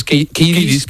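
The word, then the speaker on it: Kiedis: Kidis.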